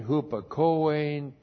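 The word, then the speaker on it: Hupakoe (ὑπακοή): And then we have tain hupakoein to Christu hupakoe, obedience, objective genitive of Christos, obedience to Christ. Hupakoe, great word. H-U-P-A-K-O long E. Hupakoe.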